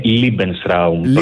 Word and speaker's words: Lebensraum: Lebensraum το έχουμε, είναι ακουστικά σε εμάς, αλλά η πιο σωστή προφορά είναι Lebensraum, προς η γερμανική εκφορά του λόγου.